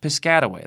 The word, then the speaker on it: Piscataway: Or I think they call it Piscataway. Piscataway, that's how you say it.